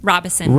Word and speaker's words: Robison: Robison. – Robison, not Robison. It's Robison. Almost like Robinson without the N.